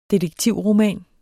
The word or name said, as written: detektivroman